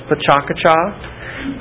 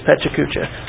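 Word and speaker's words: Pecha Kucha: And so I had said the last time we were together, I thought it was Pechakucha, but I was told by people who speak Japanese that indeed it is Pechakucha. So I guess you can pronounce it either way.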